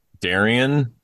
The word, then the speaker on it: Darien: Well, I did want to say, when you were talking about Darien, there's a town in Connecticut. It's spelled D-A-R-I-E-N. But if you're from Connecticut, you'll pronounce it Darien.